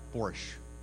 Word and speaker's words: Borscht: Just before we leave, for anyone that wants to stay, there is some, I want to make sure I pronounce it right, Borscht? That's Borscht. Okay, well, you know how to say it better than I do.